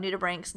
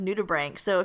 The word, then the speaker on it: nudibranch: We hope that you learned some cool stuff about nudibranchs. Number one, that is pronounced nudibranch. So if there's no other takeaway, it's that, oh, I need a TLDL. Yeah, it's pronounced nudibranchs, which means naked gills, because they have those serrata or just gills on their backside.